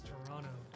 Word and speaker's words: Toronto: Oh, they hate when you pronounce that second T. – Yeah, Toronto. – It's Toronto. – Oh, no, you guys pronounce it like Toronto.